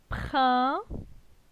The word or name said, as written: Prin